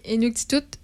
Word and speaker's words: Inuktitut: Inuktitut? Inuktitut. Ouais. Je pense qu'elle se prononce comme ça. Exactement.